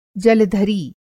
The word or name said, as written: जलधरी